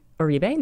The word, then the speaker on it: Oribe: So I just got the, tell me if I'm butchering the pronunciation of this, Oribe? – Oribe? No, that's right.